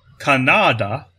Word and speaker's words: Kanada: But a philosopher in India called Kanada, it's spelled like Canada with a K, but the emphasis is supposed to be on the second syllable.